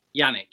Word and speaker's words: Yannick: Yannick, yeah.